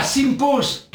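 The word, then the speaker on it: Sympos: Ah, Simpos.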